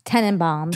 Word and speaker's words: Tenenbaums: Am I saying that correctly now? – Tenenbaums. – Tenenbaums. And it's a hard one.